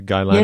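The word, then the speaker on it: Gylanic: if I'm saying that correctly, yeah, the Guylanic, yes.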